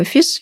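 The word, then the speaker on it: fils: название можно читать двояко, либо «фис», либо «филе». И по-французски это одно и то же слово, оно пишется одинаково, но, тем не менее, можно его понимать по-разному и читать тоже. И если мы читаем его как «фис», то это название можно перевести как «сын», а если «филь», то «нити».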